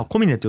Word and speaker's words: 小峰: おみねかな、こみねかな、こ小さいみねと書いて、はい、まあ、コミネと読みますね、今日は。